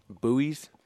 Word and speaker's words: Buies: So, Campbell University, the more you know, is in Buies Creek, North Carolina. – Buies? – It is spelled B-U-I-E-S, Creek.